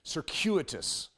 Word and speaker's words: circuitous: But circuitous technically is the proper way of saying it.